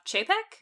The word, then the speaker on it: Chapek: Bob Chapek? – Yep. – Is that how you say it? – That is how you say it.